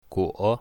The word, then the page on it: guo3: mandarin